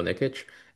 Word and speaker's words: Nikic: It's not common, so it's Daniel Nikic.